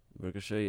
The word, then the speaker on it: Ricochet: Ricochet, yeah.